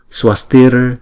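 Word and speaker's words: svastir: Chữ S và chữ V đó thì chúng ta đọc là S, W ha. Swa, Swa. Swastir, Bhavatu, Bhavatu.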